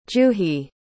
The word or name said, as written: juhi